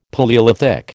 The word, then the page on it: Palaeolithic: American English